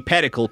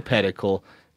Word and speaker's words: pedicle: The funny thing about pedicle is everybody pronounce it like medical, to rhyme like medical, because that's what Anthony Bubenick, a famous antler researcher, said, that it rhymes with medical.